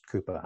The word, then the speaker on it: Cowper: It's spelled Cowper, but pronounced Cooper, I believe.